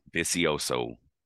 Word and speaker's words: Vicioso: Vicioso.